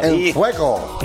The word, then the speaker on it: en fuego: En Fuego. – In Fuego.